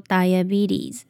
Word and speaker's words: diabetes: 那后面的 t 呢，我们会弱化它，然后第二个 e 其实也是发 e，然后最后的 s 呢要发 z 的音，所以这个字念作 diabetes diabetes。